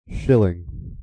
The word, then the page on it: shilling: American English